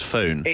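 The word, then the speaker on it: Sphoon: So, if you do want it, the company is called Sfoon because it's spoon, but they've put an H after the P. So I think it's pronounced Sfoon.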